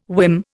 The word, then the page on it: whim: Indian